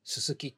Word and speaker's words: 薄: 頭が薄いの薄いって書いて、すすきって読むらしいですね。